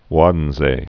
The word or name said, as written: Waddenzee